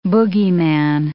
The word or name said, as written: boogeyman